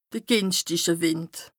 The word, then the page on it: de gìnschtiche Wìnd: Bas-Rhin; Strasbourg